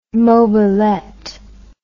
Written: mobilette

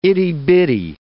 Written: itty-bitty

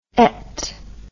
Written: ette

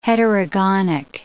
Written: heterogonic

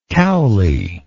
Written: Cowley

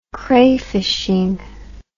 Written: crayfishing

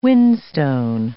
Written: whinstone

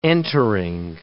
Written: entering

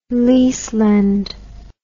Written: lease-lend

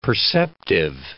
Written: perceptive

